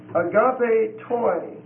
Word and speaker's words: agapetoi: Agape toi. A-G-A-P-E-T-O-I. And it's a long E. A-G-A-P-E-T-O-I.